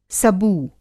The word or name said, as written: सबू